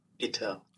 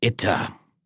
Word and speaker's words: Itter: And just for fun, let's see what Forvo says about the pronunciation of this castle. – Itter. – I got it. Itter.